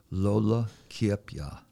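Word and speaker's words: Lola Kiepja: And when we were putting together that film, you told me that the last speaker that was known was named Lola Kiepya. – Yeah. – Did I say that right? – Yeah, you said it right. Yeah. That's right.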